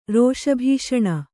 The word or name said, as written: ರೋಷಭೀಷಣ